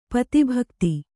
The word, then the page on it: ಪತಿಭಕ್ತೆ: pati bhakte